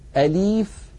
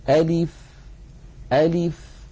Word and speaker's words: ألف: أيضا بعض إخواننا يقولون ألف ألي بإمالة، ألف بدل أن ينطق اللام مكسورة ينطقونها بحركة ممالة ألف، فلا يصح أن نقول ألف ولا يصح أن نقول أليف. نعم كأنها ياء. نعم. وبعض إخواننا يعكسونها فيختلسونها، يقولون رفلة، يخطفونها خطفا، وقد مر معي نماذج من هذا. إذن كل هذا لا يصح أن ننطقها نطقا معتدلا. زمن الفتحة في أ مساو لزمن الكسرة في لي مساو لزمن البقية الحركات. ألف ألف هكذا من غير تكلف.